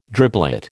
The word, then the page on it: driblet: American English